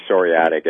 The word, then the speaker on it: psoriatic: Yeah, it's basically psoriatic.